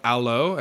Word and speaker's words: Allo: It's called Allo, as in like hello with an A.